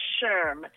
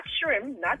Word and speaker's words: SHRM: Yes, it is SHRM. – It's so, SHRM, okay. – It's not SHRM, SHRM. And it's the acronym for Society for Human Resource Management, SHRM.